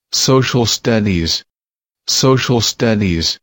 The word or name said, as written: social studies